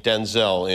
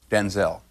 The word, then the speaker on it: Denzel: Because, wait a minute, because my name's not pronounced Denzel. My name's pronounced Denzel.